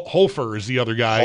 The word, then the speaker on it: Hofer: This guy's name is pronounced Hofer. – I think that's right. – It should be. – I think so. – H O F E R. It should be H O F E R. Hall of Fame.